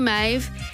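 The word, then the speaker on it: Meadhbh: This particular Maeve, you would fall over your tongue if you tried to figure out how to pronounce her name the way it's spelled. So it's M-E-A-D-H-B-H.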